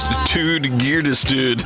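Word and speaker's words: Ted Gärdestad: Who said pronounced Tud Geardestad? – That's how it's pronounced.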